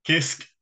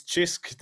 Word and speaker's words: Cisk: It's chisk, they pronounce it, and I got it after a while.